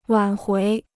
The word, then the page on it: 挽回: wǎn huí